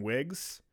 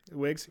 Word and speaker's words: Whig: You know, a lot of shit, you know, is kind of changing towards the modern Whigs. And I don't even know if I'm saying that right. W-H-I-G. – It's a Whig. – Whigs? Yeah, okay.